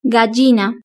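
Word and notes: gallina: Spanish (Spain)

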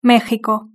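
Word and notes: mexico: European Spanish

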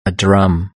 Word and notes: a drum: British English

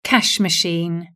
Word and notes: cash machine: British English

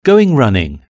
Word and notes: going running: British English

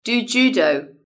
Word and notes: do judo: British English

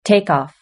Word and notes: takeoff: British English

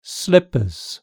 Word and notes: slippers: British English

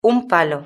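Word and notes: un palo: European Spanish

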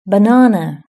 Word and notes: banana: British English

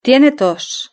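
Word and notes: tiene tos: Spanish (Spain)

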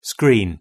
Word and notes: screen: British English

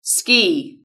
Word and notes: ski: British English

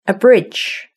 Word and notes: a bridge: British English

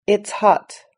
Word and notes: it's hot: British English

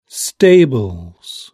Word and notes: stables: British English

